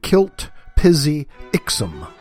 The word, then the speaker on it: Mxyzptlk: Okay, first of all, the name is spelled Mxyzptlk. Okay, no vowels. So, you put your vowels in, so it's Mix, Mxyzptlk, I-C-K. Mxyzptlk. Mxyzptlk. Mxyzptlk. And when you say his name backwards, when you make him, rather, say his name backwards, that's how you get rid of him and send him back to the fifth dimension for at least 90 days. His name is pronounced Kilt-Pizzi-Ixum. Backwards. Kilt-Pizzi-Ixum. Kilt-Pizzi-Ixum. Kilt-Pizzi-Ixum. So Mxyzptlk, when pronounced backwards, is Kilt-Pizzi-Ixum.